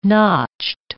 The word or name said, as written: notched